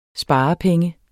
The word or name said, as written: sparepenge